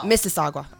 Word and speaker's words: Mississauga: 2011, Triumph Lane in Mississauga, Ontario was officially dedicated in honor of the band. Oh. Mississauga. I'm sorry, I said that wrong.